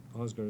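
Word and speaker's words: Aasgaard: That's, Osgard is spelled A-S-G-A-A-R-D, and his initials were J.A.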